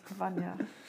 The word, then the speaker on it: Vanya: Wie spricht man das aus? – Vanya.